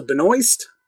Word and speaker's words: Benoist: Next thing here, Supergirl star Melissa Benoist, which I finally learned how to pronounce her last name. – Oh, her name rhymes with Moist.